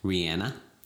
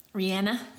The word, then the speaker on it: Rihanna: Or as she says in her native tongue, Rihanna. – Rihanna. – Yeah, that's how you're supposed to pronounce it, but sorry.